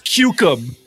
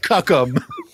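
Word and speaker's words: Cuck'em: It's pronounced Cucum. By the way, it's spelled C-U-C-K apostrophe E-M. It's spelled like cuckum, but it's pronounced cucum.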